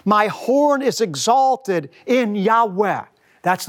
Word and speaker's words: Yahweh: Most scholars believe it's pronounced a reverent, breathy Yahweh.